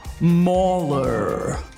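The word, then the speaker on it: Marler: His name will be Mahler. Rhymes with trawler.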